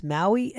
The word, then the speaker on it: Maue: So there's a meteorologist named Ryan, I think his name is pronounced Maui, M A U E.